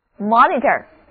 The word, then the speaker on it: monitor: 第一个字母 O 读作 O，而字母 I 的读音是短的 I， Monitor， Monitor。